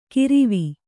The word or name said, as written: ಕಿರಿವಿ